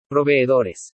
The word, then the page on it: PROVEEDORES: spanish